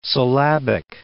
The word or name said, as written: syllabic